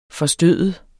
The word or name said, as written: forstøde